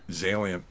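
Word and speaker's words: Xalient: I hope I say this right. Zalient? – Zalient, yes. – Pretty good there. Starts with an X.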